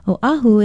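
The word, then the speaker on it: Oahu (Oʻahu): Oahu is spelled O-Okina-A-H-U. Although it is often disputed, many say that it means the gathering place. The main thing is to pronounce it correctly, Oahu.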